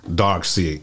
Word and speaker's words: Darkseid: Let's let's talk about my man Dark. Side.